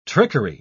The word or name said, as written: trickery